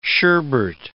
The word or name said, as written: sherbert